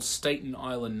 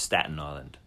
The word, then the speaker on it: Staten Island: Like most superheroes, 32-year-old Staten Island native Chris Pollock takes on a separate identity. Sorry, I think it's pronounced Staten Island. – What did I say? Staten? – Staten. – Sorry, Staten Island.